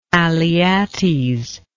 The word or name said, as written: alyattes